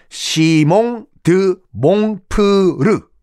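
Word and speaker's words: Simon de Montfort: 시몽 드 몽포르라는 인물이 있어요. 정확하게 발음하면 시몽 드 몽프르라는 인물이에요.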